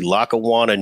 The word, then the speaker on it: Lackawanna: I want to hear about Ron Jaworski, the kid, born and raised and tell me if I say it correctly, Lackawanna, New York. – That is correct.